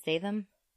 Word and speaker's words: Statham: But, like, they are just, I mean, they are humans, and they have their own abilities, like The Rock and Jason Statham and, is that how you pronounce it? Statham? – Yeah, Statham.